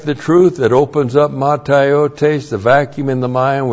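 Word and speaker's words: mataiotēs: Blackout of the soul is the believer saying no to Bible doctrine, which then opens up matiotes. M-A-T-A-I-O-T, long E-S, matiotes.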